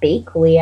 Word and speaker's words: Bakley: Robert Blake's wife, Bonnie Lee Backley, was shot. – I think it was Bakley, actually. – Was it? – Yeah. – It's spelled B-A-K-L-E.